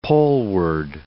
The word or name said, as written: poleward